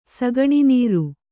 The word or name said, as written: ಸಗಣಿನೀರು